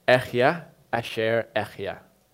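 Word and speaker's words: Echya Asher Echya (אהיה אשר אהיה): The Hebrew is Echya Asher Echya. Okay? You don't need to try to pronounce that. It's very breathy, you'll spit on people, okay?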